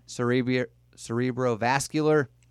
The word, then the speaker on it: cerebrovascular: I was struggling to figure out how to properly pronounce cerebrovascular.